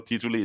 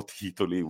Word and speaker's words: titoli: Zero tituli is like a very badly pronounced zero titoli, which is zero titles.